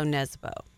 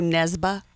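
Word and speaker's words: Nesbø: Joe Nesbo. And I think it's Joe Nesba, actually.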